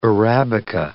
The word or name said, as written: arabica